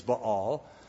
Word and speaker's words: Baal: We would say Baal or Baal. Probably the right pronunciation is Baal.